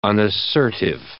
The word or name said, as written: unassertive